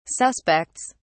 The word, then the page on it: suspects: British English